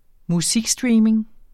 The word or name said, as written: musikstreaming